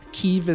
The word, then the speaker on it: Kyiv: I grew up pretty fascinated by the Eastern Front in World War II and always read or heard the capital of Ukraine pronounced as Kiev, which is the Russian pronunciation. Kyiv is the Ukrainian pronunciation.